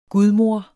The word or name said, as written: gudmor